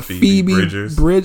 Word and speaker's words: Phoebe Bridgers: Phoebe Bridgers. That's how you say it.